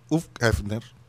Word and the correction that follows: Hugh Hefner: Hugh Hefner. Hugh Hefner.